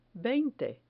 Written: veinte

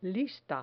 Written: lista

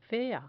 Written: fea

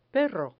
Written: perro

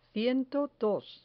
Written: ciento dos

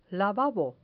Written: lavabo

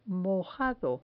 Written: mojado

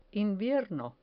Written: invierno

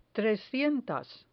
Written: trescientas

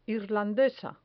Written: irlandesa

